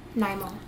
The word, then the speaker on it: Nymo: N-Y-M-O. – NIMO.